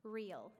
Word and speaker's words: real: Our currency is the real, real. It's spelled real.